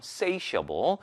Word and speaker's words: satiable: 자, 요거 발음 satiable이라고 합니다. satiable 아니고요. satiable.